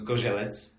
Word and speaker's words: Zgorzelec: And we say it's Zgorzelec. – Zgorzelec? – Yes, it's difficult to pronounce.